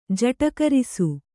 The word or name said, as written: ಜಟಕರಿಸು